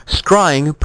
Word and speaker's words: scrying: Scrying, pronounced to rhyme with crying,